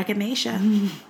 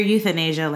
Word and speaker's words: echinacea: Well, just take your echinacea. Not your euthanasia, like I used to always think it used to be pronounced as.